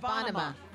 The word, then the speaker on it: Bonnema: Tonight, Leah, who I always want to mispronounce her last name, but it's Bonema. But it's Bonema. – Leah like Bonnaroo.